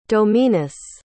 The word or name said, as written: dominus